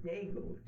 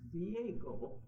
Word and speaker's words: Diego: I never heard the word Dago till I got down here, you know. And my father, when he heard it, said to me, these dumb Americans, they can't even pronounce his name right. It's Diego.